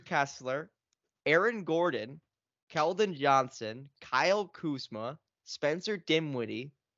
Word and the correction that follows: Dinwiddie: Um, Dinwiddie?